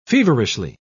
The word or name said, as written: feverishly